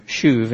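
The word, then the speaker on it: shuv (שוב): That's that Hebrew shuv, and if you are taking notes, it's S-H-U-B, but in Hebrew, you pronounce a B like a V. So it's shuv, and it's a long U.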